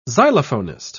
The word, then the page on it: xylophonist: British English